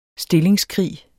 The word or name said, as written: stillingskrig